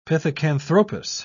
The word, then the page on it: pithecanthropus: British English